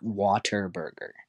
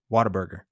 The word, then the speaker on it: Whataburger: It's spelled what a burger, but if you're from the South, you say Whataburger. And I'm not saying water burger. I'm saying what a burger.